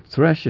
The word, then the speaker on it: thresh: Is it thresh or thrash? I think it's thresh. They thresh out the grain, but it's called the thrashing floor. Thrashing, you see? But thresh is pronounced thresh.